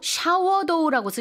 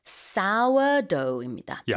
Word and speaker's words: sourdough: 근데 사실 한글로 샤워도우라고 쓰셨는데 샤워가 아니라 이게 쓰다는 그 단어 S-O-U-R라서 사워도우입니다.